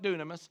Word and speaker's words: dunamis: But then there's this word called dunamis, or dunamis, however you want to pronounce it. I call it dunamis.